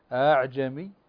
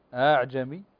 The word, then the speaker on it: أعجمي: النون المخفاة أو النون الخفيفة، وعندنا الهمزة التي بين بين وهي التي يطلق عليها بالهمزة المسهلة، هذه الهمزة التي بين بين التي ننطقها بهذه الطريقة، انتبهوا معي، أعجمي أعجمي، فهذه الهمزة تسمى همزة بين بين أو الهمزة المسهلة.